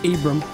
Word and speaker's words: Ibram: Is it Ibram X? – Ibram.